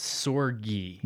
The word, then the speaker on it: Sorge: It's so stupid. It's Sorge. – No, it's not stupid. Like a hard G.